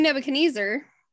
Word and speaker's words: Nebuchadnezzar: King Nebuchadnezzar. – You were saying it correctly. Nebuchadnezzar's correct.